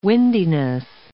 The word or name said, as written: windiness